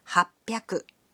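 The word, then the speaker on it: はっぴゃく: But the pronunciation can be a bit tricky. 300 is pronounced sanbyaku. 600 becomes ropyaku. And 800 is pronounced hapyaku. On to thousands.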